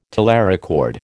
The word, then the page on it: telerecord: American English